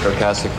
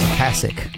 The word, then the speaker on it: Ocasek: A lot of people are confused about how his name's pronounced. – Hi, it's Rick Ocasek from The Cars. – Ocasek. Got to say with an American accent.